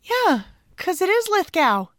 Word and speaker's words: Lithgow: And just so we have it all set and straight before we get started, it's pronounced Lithgow.